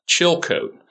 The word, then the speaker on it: Chilcote: Chilcote.